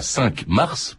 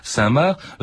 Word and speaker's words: Cinq-Mars: Je précise aussi que on dit parfois 5 mars, parce que ça se prononce comme ça, c'est INQ comme le chiffre et Mars comme la planète, mais ça se prononce euh, Saint-Marc, euh, Françoise Hildesheimer.